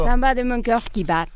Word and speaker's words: Samba de mon cœur qui bat: Samba de Monquer qui va eh, el samba de mi corazón que late. – ¿Lo dije bien? – No. – No, entonces dilo tú, a ver. – Va de nuevo. – Samba de mon cœur qui bat.